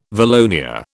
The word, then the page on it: valonia: British English